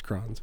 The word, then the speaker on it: Crohn's: Cronus? – It looks like it's pronounced Crohn's. – Cronus? – It's Crohn's disease.